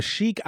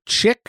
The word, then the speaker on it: chic: Or chick. I don't know how to pronounce that word.